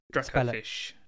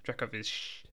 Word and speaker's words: Dracovish: Dracofish. – Dracovish.